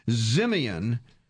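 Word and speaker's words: Ximian: In 2001, a couple of years later, they renamed Helix Code Zimian. I had to check on how to pronounce this one. Z-I-M-I-A-N. – Yeah. – And it's I think you got it.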